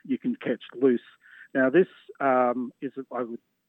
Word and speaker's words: Luce: It's Loose, but it's spelled L-U-C-E.